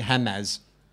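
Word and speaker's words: Hamas: If I saw that written down, H-A-M dash M-A-S, I'd be Hamaz.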